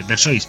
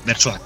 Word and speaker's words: Versoix: Lo castellanizamos, o sea, lo decimos tal y como se escribe, Versoix, pero sí que es verdad que la ciudad se dice Versoix.